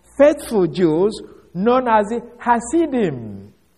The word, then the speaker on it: Hasidim: Hasidim. H-A-S-I-D-I-M. Although, this word, you may find it some other place, they may have it as C-H, Hasidim. This is because of the hard pronouncing of the the Hebrew, ha. So they can either you use it as H or CH.